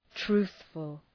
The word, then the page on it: truthful: ‘tru:ɵfəl